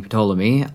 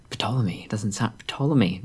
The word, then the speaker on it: Ptolemy: As you may have guessed, in Greek, the P in Ptolemy isn't silent. So that was surprising. Ptolemy does not sound right.